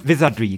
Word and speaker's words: Wizardry: Also auch so ein, so ein deutsches Rollenspiel und vor allem Wizardry. Ich weiß nicht, ob ich das richtig ausspreche. – Wahrscheinlich sieben. Wizardry, oder? Wizardry, genau.